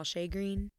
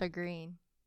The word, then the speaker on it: shagreen: All right, so the next corneal finding that you can find with an aging cornea is crocodile shagreen. – It's pronounced shagreen.